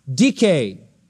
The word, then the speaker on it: Dike: Justice is the name of a goddess, Dike, D-I-K, long E.